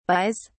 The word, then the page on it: buys: British English